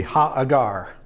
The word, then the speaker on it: Hagar: Hagar. Actually, her official uh, Hebrew pronunciation would be Ha'agar.